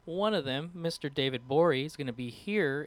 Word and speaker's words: Gborie: Go check out David Borey. It's spelled G-B-O-R-I-E. The G is silent, which is his Twitter handle.